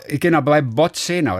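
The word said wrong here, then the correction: Božena: Bo, äh, also eigentlich heißt das Bojena. – Ah, Bojena. So, also, das wäre jetzt auf Slowakisch halt, ne? Also ich meine, es wird doch in jeder anderen Sprache dann auch wahrscheinlich anders ausgesprochen, aber äh, in, auf Slowakisch würde das Bojena heißen.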